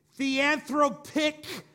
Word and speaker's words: theanthropic: He is the theanthropic person. That's right. Make sure I said it right. He is the theanthropic person. Theo means God. Anthro means man.